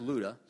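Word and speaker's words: Lydda: Now, as Peter went here and there among them all, he came down also to the saints who lived I know it's L-Y, but it's pronounced Luda.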